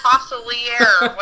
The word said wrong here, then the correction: Facilier: whatever it is. And it says... – Facilier.